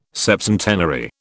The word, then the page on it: septcentenary: British English